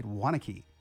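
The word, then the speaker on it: Waunakee: And I looked it up. That's how you say it, Wanakee.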